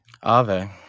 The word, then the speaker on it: Aave: The name of the company, and this is in the Wall Street Journal, it's spelled AAVE. – Mm-hmm. – How would you pronounce that? Ave? – Ave.